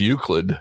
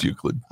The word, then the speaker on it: Euclid: I hope that's how I pronounce that correctly. Ohio. – How's it spelled? – Euclid. – Well, I'm with Euclid. It's Euclid. – Euclid. – Euclid. – Well, it's Euclid. – I'm from Euclid.